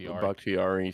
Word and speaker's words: Bakhtiari: Bakhtiari three.